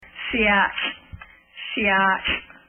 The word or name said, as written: Seattle